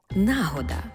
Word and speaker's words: нагода: До слова, Борис Грінченко на сторінці 477 другого тому свого словника подає іменник «нагода» і чомусь з наголосом на першому складі, як відповідник російських слів «случай», «удобне время».